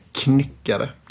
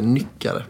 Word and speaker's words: Knyckare: Så att, det är uttal, det är inte som knock, utan det är Knyckare. Det är inte Nyckare.